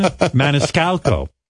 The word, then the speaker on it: Maniscalco: Sebastian Maniscalco. I said it right. – You did.